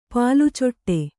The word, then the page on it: ಪಾಲುಚೊಟ್ಟೆ: pālu coṭṭe